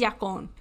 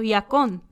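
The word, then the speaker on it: yacón: I think it's yacon because it's from South America and there's a little accent on the O. So yacon.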